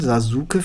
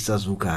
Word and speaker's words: Sasuke: Wir sprechen es so aus, wie wir es in der deutschen Synchro gelernt haben, auch wenn er nicht Sasuke heißt, sondern... – Sasuke. – Sasuke, genau. Das U spricht man nicht, aber das wusste damals die deutsche Synchro nicht, deswegen hieß er Sasuke.